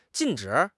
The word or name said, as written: jin4 zhi3